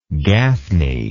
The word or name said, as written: gaffney